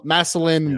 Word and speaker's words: Massillon: He played at uh, what's what's Tommy's former school, Mas- Massillon, or how, how, do you pronounce that? Massillon, yeah. Washington, or whatever.